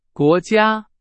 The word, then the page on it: 国家: Chinese